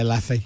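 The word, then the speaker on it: Leffe: Leff? How do you say that? – Yeah, I always say Leffy. – Leffy, Leff.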